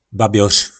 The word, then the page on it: babjor: po nasimu